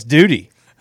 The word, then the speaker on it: Dudy: Duty? Not Dudley. – That's what I'm asking. – Spell it. – D-U-D-Y. – D-U-D-Y. – Oh, there's no L? It's not. – I thought it was Dudley. – Duty? That can't be right. – No, it's Duty. D-U-D-Y.